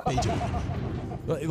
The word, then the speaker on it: Beethoven: Beethoven. Like, his first name was Bait. – Yeah. Hand me a pencil. – Last name, Hoven.